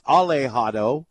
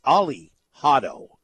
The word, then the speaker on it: Alihato: It's not pronounced Alejado, it's Alihato, just so folks know.